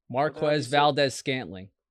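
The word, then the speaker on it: Marquez Valdes-Scantling: He might hit Scandal's touchdown things like plus 1,200. So that's... Or is that I said his name right? – Marquez Valdez Scantling. You were close.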